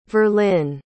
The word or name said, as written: verlin